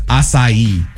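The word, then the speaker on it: açaí: A-C-A-I. It's acai. – Oh, acai.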